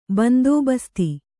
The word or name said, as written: ಬಂದೋಬಸ್ತು